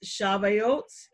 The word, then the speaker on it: Shavuot: So now we talk about the Feast of Weeks or Pentecost, which is most commonly known as, and it's pronounced Shavuot, S-H-A-V-O-O-O-T-E, Shavuot.